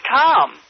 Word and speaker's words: Tom: My name's Tom. – How do you spell that? – T-O-M, just like it sounds.